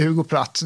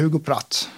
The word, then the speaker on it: Hugo Pratt: Alltså, vi säger Hugo Pratt.